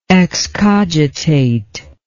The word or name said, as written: excogitator